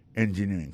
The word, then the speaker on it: engineering: Electrical engineering. C'est sur le deuxième syllabe là. Elect là, plus appuyé. Electrical engineering. Engineering, ça, l'accent tombé le même sur avant dernier syllabe là.